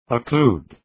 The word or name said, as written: occlude